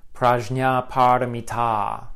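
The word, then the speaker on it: prajñāpāramitā: I should emphasize, it's prajna paramita. The long a at the end is significant because it indicates that it's a feminine word.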